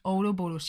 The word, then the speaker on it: Ouroboros: Ouroborus. Ouroboros. Siempre tiro a decir Aurora Borealis.